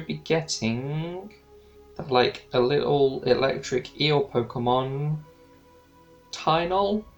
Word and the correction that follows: Tynamo: Tynamo, yes.